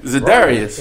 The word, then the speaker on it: Za'Darius: With that being said, Za'Darius and Judon, like, and you know, I'm Zacharias. – Notice I said his name right, Za'Darius.